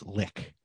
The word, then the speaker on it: lich: Yeah, and the hardest one for me to accept was lick. – Oh, yeah, lich, huh? – Yeah, it's lick, and that's just the way it's pronounced. It's a German word.